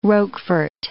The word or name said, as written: Roquefort